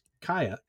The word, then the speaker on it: Kaja: Well, so what was great, he and his wife, Kaya, which is spelled K-A-J-A. So for the longest time, I thought it was Kaja, but it's Kaya. That's the way.